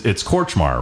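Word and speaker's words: Kortchmar: You know, and for those that don't know, Danny, it's Korchmar, right? Am I saying that right? – That's right.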